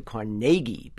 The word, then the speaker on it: Carnegie: But when you get beyond the hall and the deli, you got to say Carnegie, because he's a Scotsman, and he pronounced his name, and his nickname was Naig, N-A-I-G.